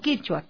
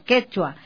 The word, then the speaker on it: quechua: Por esta dificultad de pronunciación en el aymara y el quechua peruano, quechua, no quichua, quechua, a las palabras cuya con y y cuya con doble L, se pronuncia como coya y coya con doble L, ¿no?